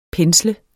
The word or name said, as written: pensle